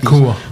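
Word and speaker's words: Nacua: We were all just kind of, how can you say this, Pakua? How do you say the rookies? – Nakua. – Nakua. – Yeah, Puka Nakua.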